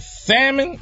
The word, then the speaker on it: salmon: So I ordered her the shrimp, and I had the salmon. Yes, I said salmon, people, because the L is silent.